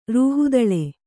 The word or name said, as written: ರೂಹುದಳೆ